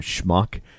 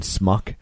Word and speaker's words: schmuck: Like, there's a funny scene in the movie where Dustin Hoffman is trying to teach Warren Beatty how to say the word schmuck, because he keeps saying smuck,